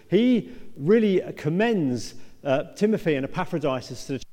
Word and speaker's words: Epaphroditus: We're looking at Timothy and a guy called Epaphroditus.